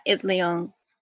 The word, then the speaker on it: Itleong: Larry Itleong? – Yeah, Itleong.